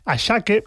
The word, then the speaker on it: Asake: Por cierto, el tema de los nombres Ashake, que se escribe Asake,